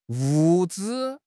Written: 物资

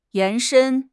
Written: 延伸

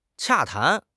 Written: 洽谈

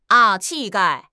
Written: 气概

